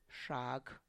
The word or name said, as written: xag